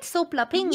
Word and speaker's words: soplapinga: Am I saying it right? Soplapinga.